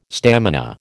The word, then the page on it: stamina: American English